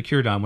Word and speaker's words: Círdan: But it's actually Círdan. Whenever you see a C by itself like that, it's pronounced as a K sound, right?